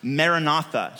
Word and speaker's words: Maranatha: And then when he says, come Lord, that's the phrase there is actually an Aramaic phrase that's pronounced Maranatha.